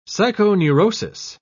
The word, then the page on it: psychoneurosis: British English